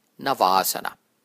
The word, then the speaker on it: navasana: Let's take boat pose, navasana. Again, in this one, the second A is long, navasana.